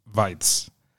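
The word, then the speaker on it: Waitz: Hace tiempo aquí en Noruega había una, o sea, una campeona de maratón, una mujer que nada, corría mucho maratón, se llama Mete Weitz, pero se escribe igual que Waits, que Tom Waits.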